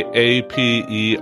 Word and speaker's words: aperitif: Aperitif is pronounced as aperitif. It is a French word that is commonly used to describe a drink that is consumed before a meal to stimulate the appetite.